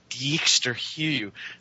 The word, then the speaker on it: Dijksterhuis: named Dijksterhuis